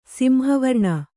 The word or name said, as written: ಸಿಂಹವರ್ಣ